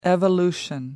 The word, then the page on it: evolution: American English